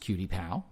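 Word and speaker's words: QTIPAL: Pronounced Cutie Pal, which I just said, The first three letters stands for, it's spelled Q-T-I-P-A-L, Q-T-Pal.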